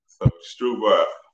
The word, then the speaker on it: Strubhar: So we just say it Strubhar, kind of like rhubarb, but it's just Strubhar, and like straw and a bar, basically. So it's Stru and bar, but yeah, it's pretty simple. But I don't blame you. It's spelled weird because it's got that extra hitchhiker.